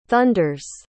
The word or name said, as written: thunders